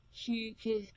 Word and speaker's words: hygge: Hygge.